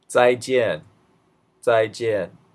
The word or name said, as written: zaijian